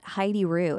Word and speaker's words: Heidi Rew: It's just at Heidi Roux, and that's spelled H-E-I-D-I-R-E-W.